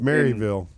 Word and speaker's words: Maryville: You know, people talk about Merrillville here, Maryville. – It's Maryville.